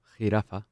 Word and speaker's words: Jirafa: Jirafa. Jirafa. Am I pronouncing that well? Jirafa. – Jirafa.